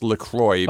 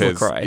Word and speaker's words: LaCroix: How do you pronounce it? – LaCroix. – Yeah, right? Anyway. – Although apparently it's officially pronounced LaCroix. – Oh, it is LaCroix. – Yeah. – Okay, well, you drink it's it. Because Americans can't spell French.